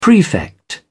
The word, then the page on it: prefect: British English